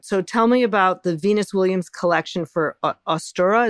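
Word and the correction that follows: Asutra: Is that how you say it? – Yes, it's called a Sutra. – Oh, a Sutra. – Yes.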